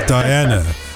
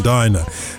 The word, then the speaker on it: Diana: he got the pronunciation wrong, didn't he? It's Diana. I mean, musically, it's got to be Diana rather than Dinah. Dinah sounds as if you're about to go for a meal in a restaurant.